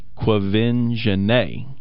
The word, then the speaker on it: Quvenzhané: Pronounced Quvenzhané.